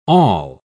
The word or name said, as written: awl